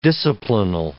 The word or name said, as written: disciplinal